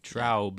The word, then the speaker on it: Traub: T-R-A-U-B. – Oh, okay. Traub.